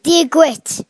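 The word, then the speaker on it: Dia duit: How do you say that again? – Diaguit. – Diaguit. Is that how you say it? – Diaguit. – Diaguit. – Yes.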